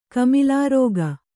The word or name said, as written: ಕಮಿಲಾರೋಗ